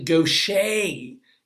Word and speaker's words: Gaucher: And now suddenly, out of nowhere, by the way, I'm not pronouncing, it's French. So it's actually Gaucher disease.